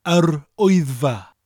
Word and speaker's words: Yr Wyddfa: Now, Wyddfa sounds like somebody shouting, Oi, it's far away from here. Wyddfa. Wyddfa. The full pronunciation for the word is Yr Wyddfa.